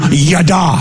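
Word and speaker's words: yada (ידע): This word, acknowledge is pronounced in the Hebrew, yada.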